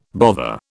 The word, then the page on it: bother: British English